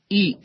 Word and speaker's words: ish: La palabra fuego, en el idioma hebreo, es, o se escribe, o se dice, esh, E-S-H. La palabra para decir hombre es ish, ish.